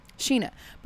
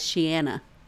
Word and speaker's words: Sheana: S-H-E-E-N-A? – S-H-E-A-N-A. – Oh, okay. Close enough. – I always, like, read it as Sheanna. – Oh, Sheanna, yeah. – But that's not right.